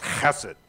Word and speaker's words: chesed: It's a Hebrew word, and I can never say it right because I can never get guttural enough, but it's chesed.